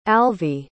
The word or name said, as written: alvy